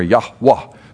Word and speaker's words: Yahweh: And the word for God, Yahweh, one of the several words, Elohim, Adonai, but Yahweh, we don't know how to pronounce. Roughly transliterated YHWH. And some of your Bibles might have vowels put in there to look like Yahweh or Yahwah, some pronounce it.